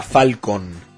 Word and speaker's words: Falken: Falcon, se escribe F-A-L-K-E-N, pero suena Falcon.